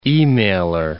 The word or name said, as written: e-mailer